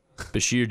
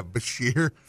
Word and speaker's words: Bashir: His name, I don't know how to say his name. It's like, Basher? – Oh. – Jihad? – Bashir Jihad. – Yeah, Bashir.